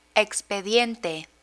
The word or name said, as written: expediente